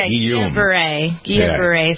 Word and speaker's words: Guillain-Barré: He has Julian, you pronounce this for me? Julian, is it Julian? – Yeah, Guillain-Barré syndrome, the neurological condition.